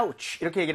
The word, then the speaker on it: ouch: 여기서 오가 아니라고요. out로 발음이 됩니다. 그래서 아. ouch 이렇게 얘기를 하면 어이쿠 아야 라고 할 수가 있는데 아하.